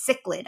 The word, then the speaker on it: cichlid: It's cichlid.